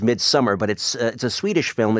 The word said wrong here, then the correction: Midsommar: It's spelled, summer is spelled S-O-M-M-A-R. Is it pronounced Midsummer? – No, it's actually pronounced Midsommar.